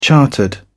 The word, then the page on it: chartered: British English